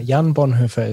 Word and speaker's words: Jan Bonhoeffer: Jan Bonhoeffer is my name.